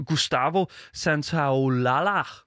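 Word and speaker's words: Gustavo Santaolalla: Øh, musikken er selvfølgelig komponeret af den gode Gustavo Santaolala, som øh, har...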